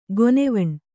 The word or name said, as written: ಗೊನೆವಣ್